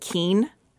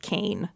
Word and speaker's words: Kean: If you don't go to Kane, you don't realize it's pronounced Kane because it's spelled K-E-A-N. So a lot of people pronounce it Keen. You know, they see the billboards of it and they're like, yeah. Oh, Keen. And it's actually pronounced Kane.